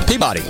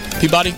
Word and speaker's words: Peabody: Peabody. That's pretty simple, right? Peabody? Peabody, really? – No, that's Peabody. I'm sorry. – Peabody? That's how you say it?